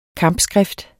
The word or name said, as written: kampskrift